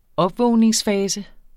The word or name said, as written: opvågningsfase